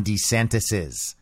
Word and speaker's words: DeSantis: And that's how you say it when you're talking about multiple Ron DeSantises. See what I mean? It's DeSantis. Like desanitize, but without that extra I.